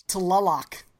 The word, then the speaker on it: Tlaloc: The 8-inch Tlaloc. – I'll take it, bro. That was good. just try to say the TL like at the same time. And then Tlaloc.